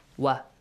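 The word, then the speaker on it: واو: Okay, we should make sure that we should not pronounce it like V. V sound, no. Like V in English. It's not that sound, okay? It's like W sound. Wa. What. When. Okay? W sound. Wa, not V.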